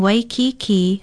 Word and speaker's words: Waikiki: Waikiki is spelled W-A-I-K-I-K-I with kaha ko over the last two vowels. Pronounce it waikiki.